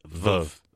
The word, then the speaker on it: Veuve: I always say it wrong. It's not... – It's not Veuve. We always say Veuve. It's Veuve. – Veuve. Yes. Veuve Clicquot. – Sorry, French people. We've been saying it wrong for years.